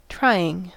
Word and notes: trying: American English

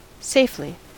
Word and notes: safely: American English

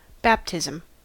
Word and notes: baptism: American English

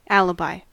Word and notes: alibi: American English

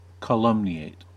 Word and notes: calumniate: American English

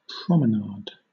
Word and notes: promenade: British English

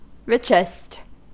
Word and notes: richest: American English

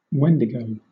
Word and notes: wendigo: British English

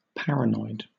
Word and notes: paranoid: British English

